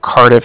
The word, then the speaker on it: Cardiff: Cardiff is spelled C-A-R-D-I-F-F, as in Frank, so it's a car and then different.